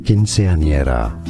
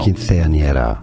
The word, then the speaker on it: quinceañera: Quinceañera. – Quinceañera. I'm trying to. In Spain, the C is pronounced like TH. – Oh. – Quinceañera.